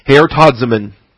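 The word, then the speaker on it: Heortazomen (ἑορτάζωμεν): It's a good word because it allows you to see both the short O omicron and the long O omega. Okay? Heortazomen. Very significant, in fact, because it is, in fact, this very omega here that tells us that we're dealing with a subjunctive verb. If it was indicative, it would be another omicron there. Okay? It would come out as heortazomen with a short O.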